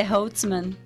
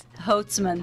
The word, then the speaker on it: Holzmann: e o sobrenome é um pouquinho difícil para eu pronunciar, mas como é que pronuncia certinho? É Holtzman. Ah, oh, mais uma vez. Holtzman.